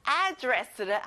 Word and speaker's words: address: 주소, address. 앞에 에 강세를 넣어보는 거예요. Address. Good job.